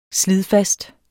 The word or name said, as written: slidfast